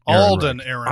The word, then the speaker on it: Alden Ehrenreich: Is it Arlen Ehrenreich? – It's Alden. – Alden Ehrenreich.